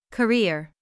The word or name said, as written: career